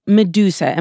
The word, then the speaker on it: Meduza: Meduza.